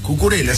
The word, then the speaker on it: Cucurella: Acá le tiene miedo a Cucurella. – Cucurella. – Cucurella, sí, lo dije bien.